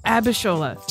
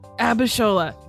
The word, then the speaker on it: Abishola: I think I said it right. – Yeah. – Abishola. – Just read the letters. – Abishola. – Yep. – Abishola.